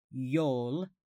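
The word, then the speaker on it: jól: Yule can be traced back at least as far as Old Norse with the word J-O-L, probably pronounced Yol,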